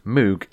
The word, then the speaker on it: Moog: This guy walked in, he had a t-shirt on it, and on it said the word Moog, M-O-O-G. Now, I've always pronounced it Moog because it's got two O's, a G, so you go Oog, and then you got the M at the beginning, so you got Moog, you know? And also, from the Magic Roundabout, there's a character called the Moog, so Moog. You get used to certain ways of saying things. Anyway, I was like, I like your Moog t-shirt. I like this. You know, I like the Moog. And he was like, actually, it's pronounced Moog.